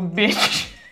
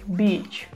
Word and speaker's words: beach: Слово «пляж», то есть «бич», произносится мягким звуком, и вы как бы его растягиваете, и при этом немножко улыбаетесь, не смыкая зубов. Бич. В слове bitch резкий и твердый звук, при этом губы как будто бы сомкнуты, и произносится это слово четко и быстро.